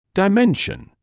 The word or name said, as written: dimension